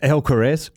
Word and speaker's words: Alcaraz: Medvedev Alcatraz, uh, Alcaraz, surely the two, and already Novak Djokovic and Jannik Sinner.